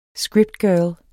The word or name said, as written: SCRIPTGIRL